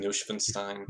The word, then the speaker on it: Neuschwanstein: Neuschwanstein Castle.